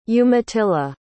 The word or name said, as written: umatilla